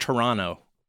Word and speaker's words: Toronto: Also, they told us that it's pronounced Toronto, not Toronto.